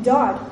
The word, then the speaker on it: dod: Dod, or dod is how it's pronounced.